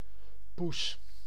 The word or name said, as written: chatte